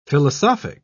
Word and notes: philosophic: British English

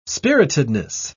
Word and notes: spiritedness: British English

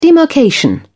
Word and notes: demarcation: British English

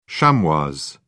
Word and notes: chamois: British English

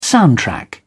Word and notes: soundtrack: British English

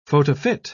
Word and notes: photofit: British English

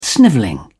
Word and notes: snivelling: English; British English

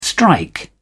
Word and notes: strike: British English

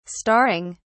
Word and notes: starring: British English

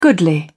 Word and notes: goodly: British English

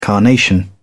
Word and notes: carnation: British English

